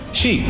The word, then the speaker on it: Xi: Rajoy nos recuerda cómo se pronuncia. – Xi. – Xi. Es Xi, Xi.